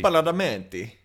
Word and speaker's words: Badalamenti: Badalamenti.